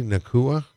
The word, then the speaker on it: Nacua: Right now it's saying Puka do you say Nacua? Is that that's officially how they say it.